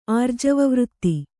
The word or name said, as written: ಆರ್ಜವವೃತ್ತಿ